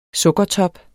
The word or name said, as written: sukkertop